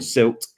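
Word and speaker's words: CILT: I'd met him at the Chartered Institute for Logistics and Transport, SILT. I've got to make sure I say that correctly. It's more worse when you write it down, to be honest. We were at the SILT Safety and Logistics Forum.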